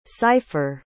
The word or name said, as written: seifer